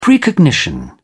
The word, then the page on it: precognition: British English